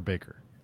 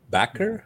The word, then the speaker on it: Bakker: Is it Backer or Baker? – Backer, I believe. – Backer, I think. – Okay. B-A-K-K-E-R.